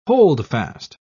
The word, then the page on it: holdfast: British English